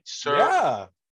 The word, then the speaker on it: Suspiria: And we just watched Suspiria. I hope I'm saying that right. – Suspiria? New one or the OG? You got it right there.